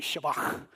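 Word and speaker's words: shabach: But if you want to say it right, you would say Shabak.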